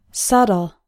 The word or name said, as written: subtle